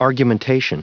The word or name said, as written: argumentation